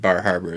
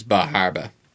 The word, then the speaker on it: Bar Harbor: You got to do the seacoast in Maine, go through, you know, like Bath and Bar Harbor, and Maine won't pronounce it Bar Harbor. It's Bar Harbor.